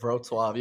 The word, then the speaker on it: Wrocław: You say it Wrocław. You have to the V. It's with a V. Yeah. – Okay, there's no chance I was going to get that then. – Wrocław.